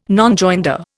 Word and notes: Nonjoinder: Indian

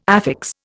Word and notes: affix: Indian

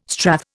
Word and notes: strath: Indian

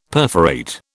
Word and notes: perforate: British English

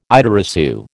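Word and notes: idée reçue: American English

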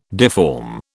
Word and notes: deform: British English

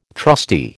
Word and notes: trustee: American English

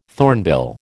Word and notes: thornbill: American English

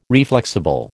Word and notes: reflexible: American English

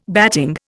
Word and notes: batting: Indian

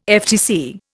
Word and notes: FTC: Indian

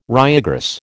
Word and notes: ryegrass: American English

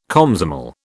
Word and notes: Komsomol: British English

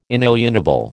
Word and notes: inalienable: American English

